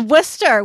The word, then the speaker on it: Worcester: We're in the Worcester. We're in the second largest city. – And for everybody who's not in Massachusetts, Worcester is spelled Worcester. – Worcester or Worcester. Yeah. Absolutely makes no sense.